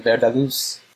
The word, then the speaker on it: VerdarLuz: Yeah, you did, VerdarLuz.